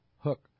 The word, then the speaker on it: Huk: This is the Hook clothing that's spelled H-U-K. – Yes. Mm-hmm. – Yeah. Hook.